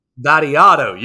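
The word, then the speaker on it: Vattiato: That's not how you say his name. Let me rewind this and hear it. Vadiato. Yes, that's what it is. Nicholas Vadiato is how you pronounce the name.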